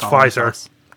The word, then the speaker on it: Pfizer: There is a P in the spelling. – Oh, but silent. – They just pronounce it Pfizer.